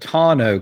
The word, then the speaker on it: tonneau: So I'm having to drop off my truck for that tonneau cover.